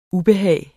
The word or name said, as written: ubehag